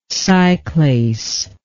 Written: cyclase

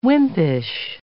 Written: wimpish

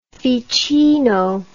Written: Ficino

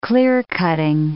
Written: clear-cutting

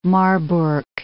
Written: Marburg

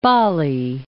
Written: Bali

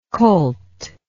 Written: colthood

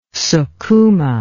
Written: sukuma

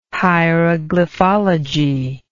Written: hieroglyphology

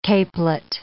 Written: capelet

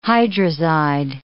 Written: hydrazide

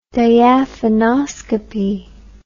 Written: diaphanoscopy

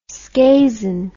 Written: scazon

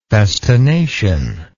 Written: festination

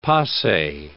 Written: pase

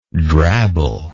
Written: drabble

